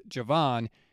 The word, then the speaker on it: Javon: It is Javon, not Javon.